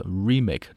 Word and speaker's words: remake: 那后面这个 remake, 注意这个词的读音啊，做名词 remake, 重音落在第一个音节啊，表示翻拍。